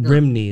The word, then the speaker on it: Rhymney: Rimney is that that's how you say it.